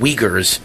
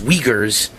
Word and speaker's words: Uyghurs: I can never pronounce their name, but I was told by several of my wonderful friends who listen to this show every hour that it's pronounced Uyghurs. I said Uyghurs. It's Uyghurs, but it's spelled Uyghurs, but it's pronounced Uyghurs, the Muslim minority group in China facing potential genocide.